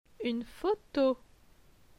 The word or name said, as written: Une photo